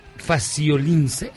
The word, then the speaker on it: Faciolince: Héctor Abad, Faciolince. Es que no sé cómo pronunciarlo. – Sí, así está bien.